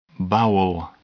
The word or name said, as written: bowel